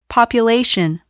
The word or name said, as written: population